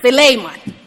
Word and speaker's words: Philemon: And it's pronounced Philemon.